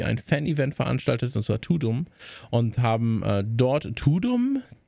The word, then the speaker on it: Tudum: Und wahrscheinlich der Gag ist, dass es halt klingt wie Tadam. aber ausgesprochen im Deutschen klingt es wie Tudum. Und das ist natürlich nicht so schön.